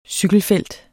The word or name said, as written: cykelfelt